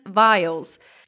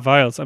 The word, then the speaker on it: vials: So was talking about the supply chains earlier and said veils, and he meant vials. – Ah, vials. I'm sorry.